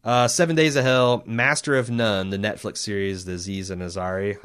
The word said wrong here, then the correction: Aziz Ansari: Is that how I pronounce his name? – Aziz Ansari, yeah.